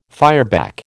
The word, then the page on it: fireback: American English